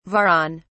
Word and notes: varan: British English

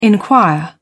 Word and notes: inquire: British English

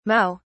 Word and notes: mao: British English

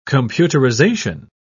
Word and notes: computerisation: British English

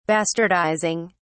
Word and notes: bastardizing: British English

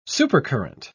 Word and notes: supercurrent: British English

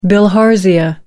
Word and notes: bilharzia: American English

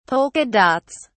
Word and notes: polka dots: British English

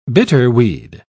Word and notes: bitterweed: British English

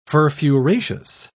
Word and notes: furfuraceous: British English

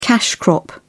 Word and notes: cash crop: British English

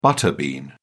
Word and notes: butter bean: British English

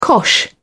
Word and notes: cosh: British English

